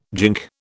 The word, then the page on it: gink: British English